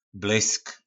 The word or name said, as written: blysk